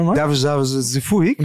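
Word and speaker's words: Deyovaisio Zeefuik: Das ist so ein bisschen wie der Rechtsverteidiger bei Hertha BSC, Davuzazu Zefuik. – Sag nochmal. – Davuzazu Zefuik. – Und genauso wird er ausgesprochen. – Er wird genauso ausgesprochen. Das könnt ihr nachschauen in der Phonetik.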